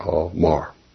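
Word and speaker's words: all Mar (אמר): The word saying is Almar, A-M-A-R, pronounced all A-W, and then Mar, M-A-R, all Mar.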